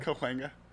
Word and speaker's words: Cahuenga: Cahuenga. – It's like C-O-W. Enga.